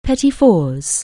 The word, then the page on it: petit four: anglais